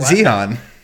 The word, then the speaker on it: Zion: Zion.